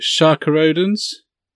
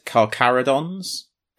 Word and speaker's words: Carcharodons: But there's a, I can never pronounce this right, Sharka Odin's novel as well. – Not that I'm the one to come to for pronunciation, but I've always heard it Carcharodons. – Carcharodons, that makes much more sense than how I've been trying to say it.